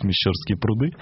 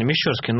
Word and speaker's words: Мещерские пруды: Светлана нас поправила, как правильно говорить, Мещерские пруды. – Ну, правильно, Мещерские. Ну, да, будем теперь знать.